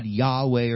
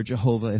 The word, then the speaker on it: Yahweh: We worship as Christians the eternal creator God, Yahweh or Jehovah, if you mispronounce the consonants, the vowels there, rather.